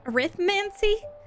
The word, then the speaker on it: Arithmancy: So for your period one, you have Care of Magical Creatures or Erythromancy. How do you say that word? Arithmancy? I got it.